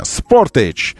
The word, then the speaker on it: Sportage: Надо себя заставлять говорить правильно. Sportage, плюс 1%.